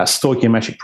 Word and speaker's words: stoichiometric: Because if they're only looking at that actual end process, a stoichiometric process, and I'm saying stoichiometric a lot because it took me a long time to work out how to say it properly.